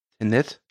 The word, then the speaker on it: Tenet: Tenet? – Ja. – Ja, hinten wie von vorne, ne? Tenet.